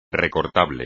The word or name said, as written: recortable